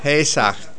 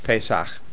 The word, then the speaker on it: Pesach: Pesach. – Pesach. I would spell it P-E-S-A-K-H because it's got the ch sound on the end. And it's the same in modern Hebrew and in Ashkenazi Hebrew. It's Pesach.